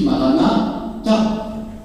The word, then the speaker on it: Maranatha: On peut dire aussi Marana, Ta. Notre Seigneur, oh notre Seigneur, vient. C'est un cri. Et vous avez vu que la finale de l'Apocalypse joue sur ces deux manières de prononcer le Maranatha.